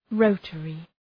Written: rotary

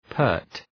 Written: pert

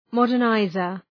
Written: modernizer

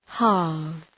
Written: halve

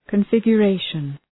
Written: configuration

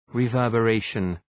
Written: reverberation

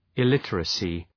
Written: illiteracy

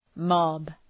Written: mob

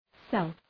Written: self

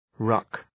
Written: ruck

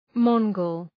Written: Mongol

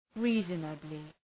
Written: reasonably

